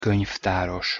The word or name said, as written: konyvtaros